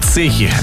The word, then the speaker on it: цехи: Да, потому что правильно говорить столяр, да, но именно столяр называют те, кто работают, да, в цехах. – Профессиональная лексика. – Да. Поэтому все-таки правильно говорить цехи, а не цеха.